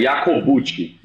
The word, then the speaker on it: Jacobucci: É, é foda, é Jacobucci, mas tem que fazer com a mãozinha.